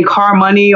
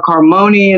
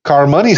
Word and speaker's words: Carmoney: It says Carmoney, like harmony. – Oh, yes. Now, I had to do that because people, they'll call me Carmoney or Carmoney, and it's like, no, Carmoney, let it flow.